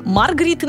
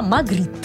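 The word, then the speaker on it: Magritte: Магрит, кстати, с двумя «т» пишется, да, еще Маргрит иногда его называют, это неправильно, да, Магрит.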